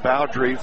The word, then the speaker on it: Bowdre: Jack Bowdre, B-O-W-D-R-E, pronounced Bowdre, first baseman for Northmore.